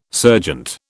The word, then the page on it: serjeant: British English